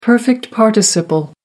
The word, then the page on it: perfect participle: American English